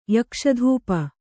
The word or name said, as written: ಯಕ್ಷಧೂಪ